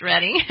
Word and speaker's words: Reading: It's spelled Reading, but it's pronounced Redding. – Right. – Like in Pennsylvania.